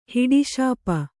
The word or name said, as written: ಹಿಡಿಶಾಪ